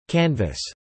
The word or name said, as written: canvas